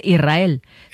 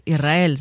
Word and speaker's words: Israel: Y la S con la R es difícil de pronunciar. La gente dice Israel, que suene la S. Israel, sí, es difícil de pronunciar.